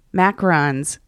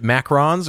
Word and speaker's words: macarons: Is it macarons or macaroons? There's 2 different ones, right? – So there is a coconut macaroon, and then there are, it's spelt macarons, but a lot of people just say macaroons.